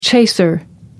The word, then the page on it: chaser: American English